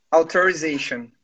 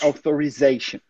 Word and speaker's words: authorization: Inclusive, essa aí é a pronúncia americana, authorization. Ali vocês podem ver que eu escrevi com um S, assim, nossa, escreveu errado, não tem nada de errado. Com S é a versão britânica, com Z é a versão americana. Mas o que eu quero dizer é que a pronúncia também muda, tá? No inglês americano, como você diz, authorization.